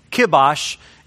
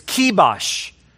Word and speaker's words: kibosh: I've been pronouncing it wrong. I always use kibosh. It's kibosh. You put the kibosh on something, we mean that you're just putting an immediate stop to it.